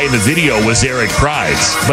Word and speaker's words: Prydz: Is it Eric Prydz? – Yeah. It's Prydz, yeah. – P-R-Y-D-S.